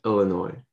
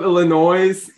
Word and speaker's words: Illinois: Did he say Illinois? – Yeah, in one of his videos, like his teaser videos for his concert, he was like, "What's up, Illinois?" And then I went up to him and I'm like, "Hey, dude, it's pronounced Illinois, by the way."